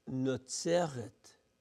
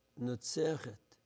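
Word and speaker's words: Nozeret (נצרת): And that's, and the town, Nazareth, we say Nazareth. In Hebrew, it is pronounced Nozeret, Nozeret.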